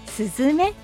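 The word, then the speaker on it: 寿寿女: すずめご紹介させていただきますことぶき二つに女と書いてスズメと読みます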